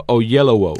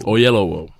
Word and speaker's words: Oyelowo: Yo lo he escuchado pronunciado Oyelowo. – Oyelowo, gracias.